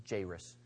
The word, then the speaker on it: Jairus: my Bible says the pronunciation is Jairus.